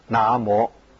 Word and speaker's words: 南无: 在梵语里面称为那摩，当时就翻成南无这两个音。我们的语言是会随着时代变的，字的形状没有变，但是读音古今就不一样，所以应该要读古音，读成那摩。